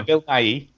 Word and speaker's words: Bill Nighy: Bill Nighy.